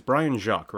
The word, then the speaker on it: Brian Jacques: It is Brian Jacques, right? – Yes.